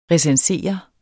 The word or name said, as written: recensere